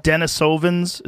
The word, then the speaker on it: Denisovans: Denisovans? Is that the name of them? Am I saying that right? – Yeah.